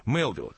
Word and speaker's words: Мелвилл: Знаете, интересно, Андрей, что американские кинокритики настаивают на том, чтобы фамилию режиссера произносить с ударением на первом слоге – Мелвилл, по-американски, потому что, в конце концов, эту фамилию он взял американского писателя.